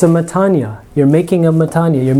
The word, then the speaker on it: metanoia: So this is sometimes, people will pronounce it like this. Metanoia. Like that. Metanoia. But in modern Greek pronunciation, this is an E sound. So you just say, and this is where the accent is. So you say metanoia.